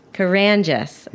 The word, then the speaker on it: Karangis: Karangis.